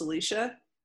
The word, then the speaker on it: Alyssa: It's spelled like Alyssa, but it's pronounced Alicia.